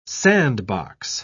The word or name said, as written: sandbox